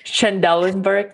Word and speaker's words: Trendelenburg: Trendelenburg, yes.